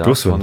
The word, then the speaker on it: Dursun: Wir haben doch noch einen neuen Stürmer verpflichtet und sind mit Serdar Dursan von. Ich muss mal aufpassen, dass ich das richtig ausspreche, ja, Dursun. – von Dursun, genau, vom Greuther Fürth zu uns gekommen.